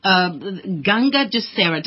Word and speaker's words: Ganga Dussehra: Well, thank you for joining us and talk to us about Ganga Dussehra.